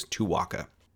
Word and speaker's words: Tuaca: And in doing my research, I even found a 1975 print ad that had a phonetic pronunciation of Tuaca to be sure that you're pronouncing it as I am, which is Tuaca.